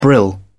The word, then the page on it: brill: British English